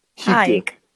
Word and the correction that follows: Hygge: Hygge. – Yes, Hygge. That's how you say it. It's spelled like hyg.